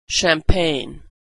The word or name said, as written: champagne